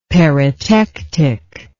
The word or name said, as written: peritectic